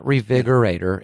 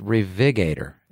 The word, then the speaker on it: Revigator: It's not Revigorator. It's Revigator. It's spelled a little messed up, but I call it the Revigorator.